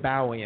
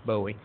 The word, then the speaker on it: Bowie: David Bowie, and I'm pronouncing it correctly according to the English pronunciation, most of you know it as Bowie.